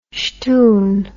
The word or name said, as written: shtoon